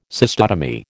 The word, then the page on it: cystotomy: American English